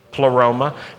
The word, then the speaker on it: pleroma: The word in Greek is pleroma. Say that with me, pleroma. It rhymes with aroma, but it has nothing to do with aroma.